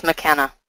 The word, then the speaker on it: Makena: It's Makena. – Makena. Okay, very good. Like Paul Makena, the hypnotist. Awesome. Okay, so the Makena Beach and Golf Resort in Maui, and time is running out to register.